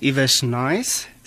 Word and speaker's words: Lewers Nice: And our name is Ivesh Nice. – So the L is silent.